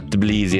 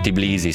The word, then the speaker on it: Tbilisi: Poi grazie, la capitale della Georgia si chiama Tbilisi e non Tbilisi, sì, però pronunciala te tutti i giorni.